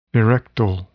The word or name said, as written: erectile